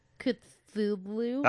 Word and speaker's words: Cthulhu: This is the part where I publicly talk about the fact that I can't pronounce Cthulhu. Cthulhu. – That's right. That's right. You got it right.